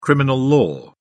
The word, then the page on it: criminal law: British English